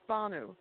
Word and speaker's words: Banu: It's Banu.